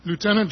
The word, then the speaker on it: Lieutenant: Lieutenant, and I use the American pronunciation, Lieutenant General Arthur Trudeau, was given the task of doing something with it.